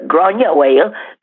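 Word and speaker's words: Gráinne Mhaol: Aber wieso habe ich die historische, also die auch noch Gronja, äh, Gronja Whale, so wird es auf Irisch ausgesprochen, ich hoffe, ich habe es einigermaßen korrekt über Gronja Whale.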